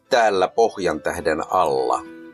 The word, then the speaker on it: Täällä Pohjantähden alla: Täällä Pohjantähden alla.